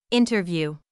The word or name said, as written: interview